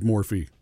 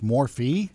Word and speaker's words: Morphe: I just see Morph when I look at it. – No, Morphe is how you say it. Anyway, so I walk into the store. – Wait a second. It's Morphe, not Morph? – It's Morphe.